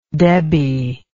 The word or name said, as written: debbie